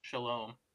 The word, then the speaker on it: Shalom: I don't make the like guttural sounds. So I would also pronounce it Hesed and Shalom.